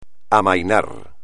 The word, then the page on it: amainar: espagnol